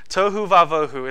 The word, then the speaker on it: tohu vavohu: So, tohu vavohu. It's spelled T O H U space V A dash V O H U.